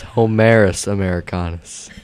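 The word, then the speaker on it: Homarus americanus: And other things that we find in the traps, you know how he said the lobster, that one is Humerus americanus. – Oh, great. – Humerus americanus. No. I can't pronounce it. – It's Humerus americanus.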